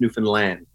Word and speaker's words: Newfoundland: It's not Newfoundland. It's Newfoundland. Newfoundland. Whatever you think it couldn't possibly be pronounced. Like yeah, that. Newfoundland. There we go.